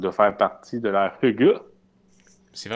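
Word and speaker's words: hygge: Euh, ben, il, nous proposait de parler du hedge, qu'il faut prononcer Hugue.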